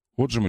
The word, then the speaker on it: отжим: Отжим или отжим? – Отжим. – Отжим. Спасибо большое. Спасибо. Мне кажется, что землю, наверное, с другим ударением.